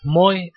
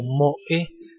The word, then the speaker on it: moe: el término moe, pronunciado en castellano como moe, no Moe, no se pronuncia Moe como el, tipo de Los Simpsons.